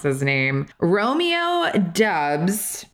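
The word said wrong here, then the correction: Doubs: Dobbs. That's how they've been saying. They've been saying Dobbs all season.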